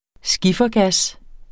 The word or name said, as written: skiffergas